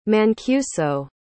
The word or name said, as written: Mancuso